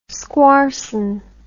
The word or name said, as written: squarson